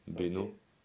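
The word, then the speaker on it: Beynost: C'était Lyon, une banlieue à côté de Lyon, Bénaud. – Ok. – Bénost, ça s'écrit.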